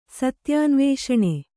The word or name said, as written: ಸತ್ಯಾನ್ವೇಷಣೆ